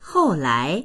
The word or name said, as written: hou4lai2